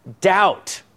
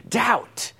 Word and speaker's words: doubt: Doubt, right? That's a tough word to say, especially with that B right there. You're like, how do you say that word, right? Doubt.